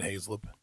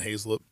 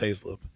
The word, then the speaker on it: Hazelip: If I'm trying to tell somebody how to pronounce it and they look at me, I'm like Hazelip. They're like Hazelwood? I'm like, no, still just Haslip.